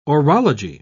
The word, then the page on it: orology: British English